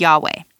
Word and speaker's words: Yahweh: I will be," and then he says that he is, in the NIV it says the Lord, but in Hebrew it says YHWH, pronounced Yahweh.